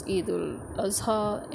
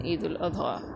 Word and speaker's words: Eid al-Adha: This is Eid al-Adha in our pronunciation, and in Arabic pronunciation, Eid al-Adha.